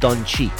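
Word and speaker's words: Dončić: By the way, his last name is correctly pronounced Doncic.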